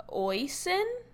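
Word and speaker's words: Oisín: But I did see at BravoCon that Oisin, is that how you pronounce this person's name? – Mm-hmm.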